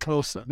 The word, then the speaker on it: Xhosa: That's how you say it, Xhosa.